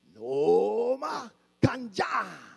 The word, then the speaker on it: noma kanjani: He says, that's okay, but you need to say it like a Zulu. So I will say it, and then you have to say it with me and like me. Okay? Noma Kanjani.